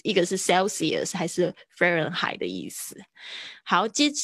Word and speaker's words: Fahrenheit: 对啊，飞飞，然后 H 不发音呢，飞轮轮 Fahrenheit，这边是发 AI 的声音，这个字可能是德文来的 Fahrenheit。